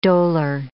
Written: dolor

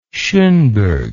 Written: schonberg

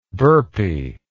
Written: burpiness

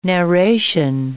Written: narration

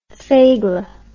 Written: faygeleh

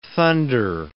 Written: thunder

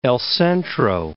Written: El Centro

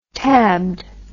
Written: tabbed

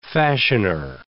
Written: fashioner

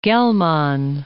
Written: Gell-Mann

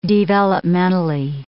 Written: developmentally

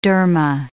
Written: dharma

